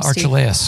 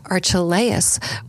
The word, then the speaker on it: Archelaus: Archelaus. – Archelaus was ruling over Judea in the place of his father Herod.